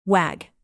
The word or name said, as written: wag